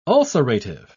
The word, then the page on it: ulcerative: British English